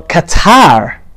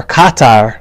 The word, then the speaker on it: Qatar: Well, Qatar, or Qatar, mm-hmm. as, as, plenty of Americans say,